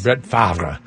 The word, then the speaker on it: Favre: Brett Favre is sort of how you say it. I know it doesn't look like that.